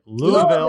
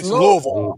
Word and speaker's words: Louisville: I always mispronounce it. Louisville. – Louisville. – Do I do it right? – No. It's Louisville. – Louisville. Yeah. Louisville. – You can't say Louisville. V-U-L. Louisville.